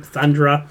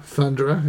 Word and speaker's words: Thundra: Thundra. – Thundra, yeah.